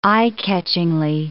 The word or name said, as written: eye-catchingly